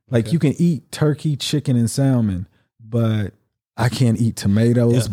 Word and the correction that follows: salmon: They're gonna be like, the L is silent.